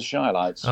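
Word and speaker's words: Chi-Lites: The Chillites have popped into my head for some reason. – You've got the name wrong for a start. It's the Shylights.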